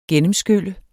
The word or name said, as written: gennemskylle